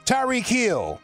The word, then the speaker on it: Tyreek Hill: Tyreek Hill.